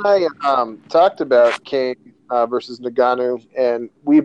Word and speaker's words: Ngannou: And the first of those being the recent bout between Francis Ngannou. Now, I never say that right. I always say it different. Ngannou.